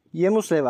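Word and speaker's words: Hjelmslev: Bu içerik ve ifade ve her birinin kendi biçim ve tözü olduğu fikri aslında bir dil bilimci Yemuslev'e ait. Hücam Slev diye yazılıyor. Yemuslev diye okunuyor sanırım.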